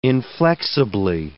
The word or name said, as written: inflexibly